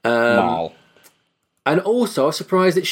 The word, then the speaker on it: Mal: And I didn't know she's called Mal, as in M-A-L. I thought she was called Moll. Like Molly, Moll, like a mole, like in a gangster film, like a mole.